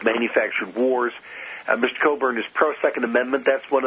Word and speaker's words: Cockburn: It's spelled Cockburn, but it's pronounced Cockburn.